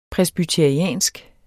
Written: presbyteriansk

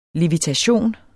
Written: levitation

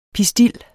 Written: pistil

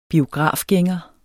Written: biografgænger

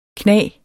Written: knag

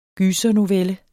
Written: gysernovelle

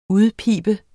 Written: udpibe

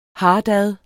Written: hartad

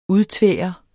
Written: udtvære

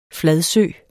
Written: fladsø

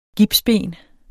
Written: gipsben

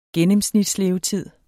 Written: gennemsnitslevetid

